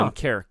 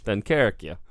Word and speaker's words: Dunkirk: It was D-U-N-K-E-R-Q-U-E. Huh. Dunkirk. Did I say that right? – Yeah. – Dunkirk.